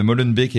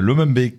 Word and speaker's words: Molenbeek: Euh, il y a donc Les Barons, dans lequel je parle bien de Molenbeek et pas de Lomenbeek, comme j'ai mal prononcé avant.